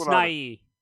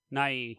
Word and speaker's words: Nighy: Nighy, or Bill Nye, however we, I think it's not, it's Nighy. Nighy.